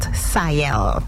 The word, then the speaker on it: SIEL: And could you give us a proper pronunciation on the S-I-E-L? – That's pronounced Sayel. – Sayel.